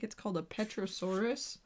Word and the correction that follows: pterosaur: No, it's a pterosaurus. Sorry, pet. – Yeah, you never pronounce those P's.